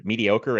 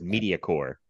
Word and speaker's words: mediocre: One time, someone I know pronounced the word mediocre as Mediacore, and we never.